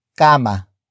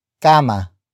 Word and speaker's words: kama: And that is kama. Kama.